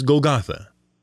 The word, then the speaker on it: Golgotha: The Greek pronunciation is Golgotha.